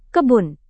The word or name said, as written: kebun